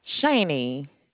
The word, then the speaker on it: Shaney: My name is Shani. Can I start by getting your zip code to pull up your account? – Yeah, good morning, Shani. Did I pronounce that correctly? – Shani, S-H-A-N-E-Y.